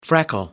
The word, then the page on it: freckle: American English